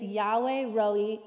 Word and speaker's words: Yahweh Rohi: The phrase, "The Lord is my shepherd," is Yahweh Rohi in Hebrew. You may have also heard it pronounced Jehovah Rohi. Jehovah and Yahweh are just two different ways of pronouncing the personal name for God.